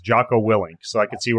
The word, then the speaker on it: Jocko: I have my you just call him Junko. – Is it Junko? Is it Jocko? How do you say his name again? – Junko's your garbage man. Jocko. J-O-C-K-O.